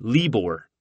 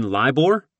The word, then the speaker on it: LIBOR: The officer pronounced it LIBOR. Hayes couldn't help himself. "You mean LIBOR?" he blurted, correcting the officer's pronunciation.